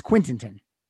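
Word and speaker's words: Quittenton: It's a very, phonetically, it's Quintington. Sounds like I'm stuttering, but I'm not.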